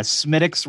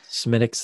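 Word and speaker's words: Smithwick's: I got a Smittix Red Ale. – Smittix, that's not what that word says. – That's how it's pronounced. – Yeah, but it's not. That's like Worcestershire. That's the same thing.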